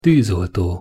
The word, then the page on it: tuzolto: Hungarian